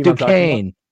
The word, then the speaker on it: Duquesne: Duquesne.